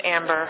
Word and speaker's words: Amber: My name's Amber. – How do you spell that? – A-M-B-E-R.